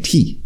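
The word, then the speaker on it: Ti: By the way, they're now calling themselves Doe and T instead of Bo and Peep. – Doe and what? Huh? – Doe and T. – T. – T. – T. Wow. – Spell T-I, but pronounced T.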